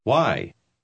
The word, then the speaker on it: Why: Why? Why? – Note que WH se pronuncia con frecuencia como hua.